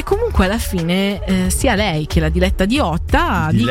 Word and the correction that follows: Leotta: pronunciamola bene, perché se hai Damiano Davidi dei Maneskin è sexy, io mi offendo se tu mi parli male di Diletta Leotta.